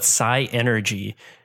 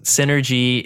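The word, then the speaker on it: Psynergy: So growing up, I used to call it psi energy, but there's no E there. It's synergy. – It's not synergy? – It's spelled P-S-Y-N-E-R-G, synergy.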